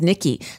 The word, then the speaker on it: Nikki: I was going to say, there's a great, my name is Nicole, but as a kid, I was Nikki, spelled the same way, N-I-K-K-I.